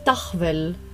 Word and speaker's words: tahvel: Auf estnisch heißt die Tafel nämlich Dachwell. Also kommt tatsächlich aus dem Deutschen und wird, also wird geschrieben T-A-H-V-E-L.